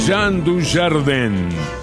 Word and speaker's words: Jean Dujardin: Jean Dujardin. Si al pronunciarlo bastante francesamente no se entiende, se escribe Jean Dujardin.